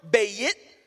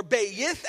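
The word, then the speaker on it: bayit (בַּיִת): And this principle at a glance might not mean anything, but this Hebrew word, bayit, or bayith, as some might pronounce it,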